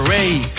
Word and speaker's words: Raye: Ray. Se escribe Raye, R-A-Y-E, con esta canción que se llama I Know You're Hurting.